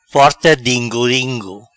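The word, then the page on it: Porta dingo dingo: Patua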